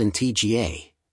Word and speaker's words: Antigua: Antigua, pronounced in TGA, and Barbuda, Barbuda, is located in the heart of the Caribbean Sea.